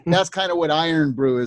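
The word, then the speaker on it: Irn-Bru: And then one of their big local drinks is called Iron Brew.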